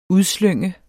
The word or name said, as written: udslynge